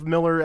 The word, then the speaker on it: Millar: because all the videos I found were calling him Millar. So I'm going with Millar as the pronunciation.